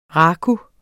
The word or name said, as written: raku